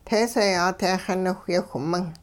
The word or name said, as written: teeseeyaa-te xiniwhyeewh-ming